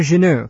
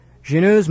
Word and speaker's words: Genoud: That's right, and it's spelled G-E-N-O-U-D.